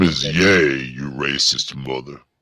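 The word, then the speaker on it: Ye: It's actually pronounced Yay.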